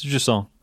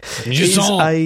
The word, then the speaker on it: Jusant: So, Jusson, how do you pronounce? How's best to pronounce Jusson? – It's Jusson, yeah. – Right, okay. – Jusson! That's how you pronounce it.